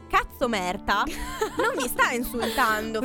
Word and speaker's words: katso: Tipo la parola cazzo. – Ma proprio, cioè, così? – Si scrive K-A-T-S-O. – Non stiamo dicendo una parolaccia.